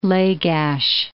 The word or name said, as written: Lagash